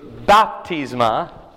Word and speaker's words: baptisma: But baptisma, and actually the pronunciation's at the beginning, so it's baptisma, right? In Greek, it would sound that way.